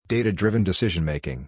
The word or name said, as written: Data-Driven Decision Making